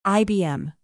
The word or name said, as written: ibm